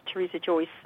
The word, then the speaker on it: Teresa Joyce: The book is called There's a Fine Line, and my name is Teresa Joyce, and that's spelled T-E-R-E-S-A-J-O-Y-C-E.